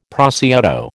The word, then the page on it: prosciutto: American English